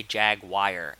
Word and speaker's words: Jaguar: Like, I say Jagwire, as in J-A-G-W-I-R-E. Like, that's the pronunciation of what that word is.